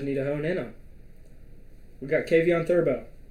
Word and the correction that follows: Thibodeaux: Is that how you pronounce his name? Kayvon Thibodeaux, yeah.